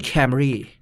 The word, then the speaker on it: Camry: Camry แต่ประเทศไทยคำว่าแคมอย่างที่คุณรู้ว่ามันแปลว่าอะไรมันเลยต้องหุกแผงเป็นคัมรี่